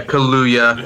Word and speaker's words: Kaluuya: And it's Kaluuya. If anyone, Kaluuya. – Yeah, Daniel Kaluuya. – Yeah, that one's fairly easy. It's as it's spelled. – Yeah. The double U.